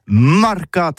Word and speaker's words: marcat: Eh bien, dis-je à tous, mais le mercat de l'Uni ou le mercat des rebelles, mais en gascogne toulousain, c'est prononcé sur les marcats, avec un A marcat.